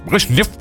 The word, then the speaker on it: Brezhnev: Brezhnev.